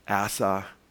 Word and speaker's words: Asa: Asa. And that's really how it's pronounced, Asa.